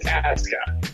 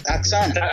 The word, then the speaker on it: Asuka: WWE is doing one thing right because Japanese pronunciation, you never pronounce the U. Okay, but it's Asuka. – Asuka.